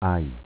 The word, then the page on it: Aji: dialetto